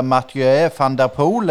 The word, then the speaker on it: Mathieu van der Poel: Jeg vet ikke om du skal prøve å uttale det navnet der, Mathieu van der Poel, er det riktig? – Det høres eh, så riktig ut som det kan få men skal vi la han unge få si litt sjøl nå etter hvert?